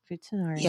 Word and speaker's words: futanari: futanari, I said it right? That's the way you say it, futanari.